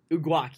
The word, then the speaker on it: Uguak: That's how you pronounce it? – Uguak, yes, Uguak.